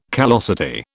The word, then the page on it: callosity: British English